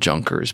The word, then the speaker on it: Junkers: It's spelled Junkers. – No, it's spelled Junkers, but you say Junkers. Almost positive.